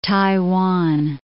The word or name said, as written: Taiwan